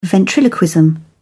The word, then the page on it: ventriloquism: British English